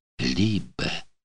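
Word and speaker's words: Liebe: «Либе». «Либе». Красивое слово. Воздух был хмельной, летний. На скамейках сидели парочки, а на газоне мелькали тени летающих голубей. «Либе».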